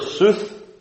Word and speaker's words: sooth: The old word sooth has the same meaning as the word with which it rhymes, truth.